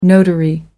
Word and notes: notary public: British English

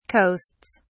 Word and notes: coasts: British English

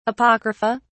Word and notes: apocrypha: British English

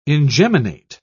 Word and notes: ingeminate: British English

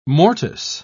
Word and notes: mortice: British English

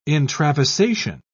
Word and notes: intravasation: British English

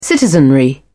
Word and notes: citizenry: British English

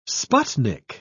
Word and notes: sputnik: British English

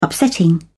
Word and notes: upsetting: British English

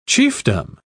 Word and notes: chiefdom: British English

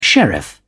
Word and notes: sheriff: British English

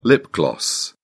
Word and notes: lip-gloss: British English

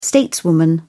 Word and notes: stateswoman: British English

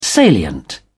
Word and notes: salient: British English

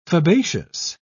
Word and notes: fabaceous: British English

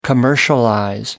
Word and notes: commercialize: American English

